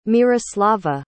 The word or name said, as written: Myroslava